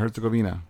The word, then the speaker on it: Herzegovina: Bosnia and Herzegovina, I said that right.